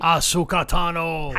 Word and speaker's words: Ahsoka Tano: So the person out there killing people in the mist like a serial killer is Ahsoka Tano. Did I say that right? – Ahsoka Tano. – How do you spell that? – It's spelled A-H-S-O-K-A.